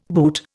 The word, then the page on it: boor: Indian